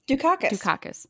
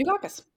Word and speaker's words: Dukakis: Dukakis. – Sally Field. – Dukakis.